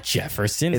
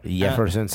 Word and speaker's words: Yeferson: It's not Jefferson. Jefferson. – Even though there is a J right there. It's spelled with a J, but it's pronounced. It's because I forgot where he's from, but it's somewhere in Central America.